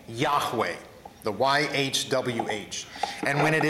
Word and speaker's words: Yahweh: This is sometimes pronounced Yahweh or Jehovah.